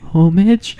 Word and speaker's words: homage: It's homage. – Really? – I made that mistake too. I did a presentation in class and I said it wrong. And my teacher's like, what'd you say? And I said, homage.